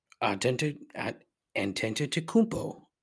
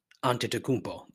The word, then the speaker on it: Antetokounmpo: I was reading the other day about Giannis Antetokounmpo. I'm saying it wrong. Giannis Antetokounmpo. There it is.